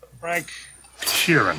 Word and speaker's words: Frank Sheeran: Frank Sheeran. Am I saying that right? – Yeah, you said right.